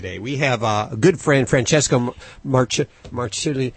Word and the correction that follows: Marciuliano: Yeah. How do you pronounce his last name? I've got a Marciuliano. I really messed it up last time, so I'm gonna I'm gonna practice before he gets on the air. Francesco Marciuliano.